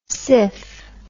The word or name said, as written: siff